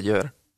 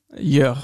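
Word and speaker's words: Győr: Und ich weiß nicht, ich werde den Club mit Sicherheit falsch aussprechen, aber Gyorgy Eto FC? – Ja, hast du recht, hast du falsch gesprochen. – Ja, heißt er richtig? – Eto FC Jör. – Jör, okay. Genau.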